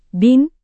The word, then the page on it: bin: indonesian